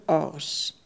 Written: Ors

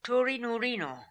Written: Toorinoorino

